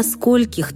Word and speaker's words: скольких: Правильно спросить «до скольких ты сегодня работаешь?» с ударением на «о».